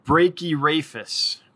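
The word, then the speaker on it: Brachyrhaphis: Brachyraphus. Brachyraphus. Okay, cool. Brachyraphus. So the H is silent.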